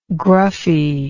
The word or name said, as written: gruffy